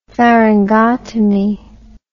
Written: pharyngotomy

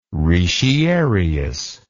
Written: retiarius

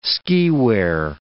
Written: skiwear

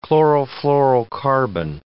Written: chlorofluorocarbon

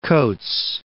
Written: Coates

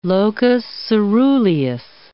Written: locus coeruleus

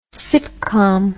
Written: sitcomm

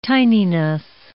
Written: tininess

